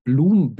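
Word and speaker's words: Bloem: Ähm, wer da ein bisschen mehr zu sehen möchte, kann unter anderem mal nach Bloom Bitcoin schauen, also mit OE geschrieben, im niederländischen U ausgesprochen.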